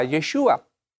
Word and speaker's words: Yeshua: Pero en la Biblia aparece como Jesús y en su época se pronunciaba Yeshua.